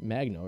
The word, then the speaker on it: Magno: They said that right? Magno, right? I thought it was yeah. Mango. I, I want to say Magnum like the condom, but I don't.